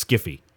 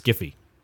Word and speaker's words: sci-fi: And he says it shouldn't even be pronounced sci-fi. It's skiffy. The way it's spelled, it should be pronounced skiffy.